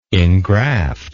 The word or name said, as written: ingraft